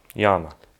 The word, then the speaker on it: Yama: But so can you say Yama? – Yama. – Great. Nice long A.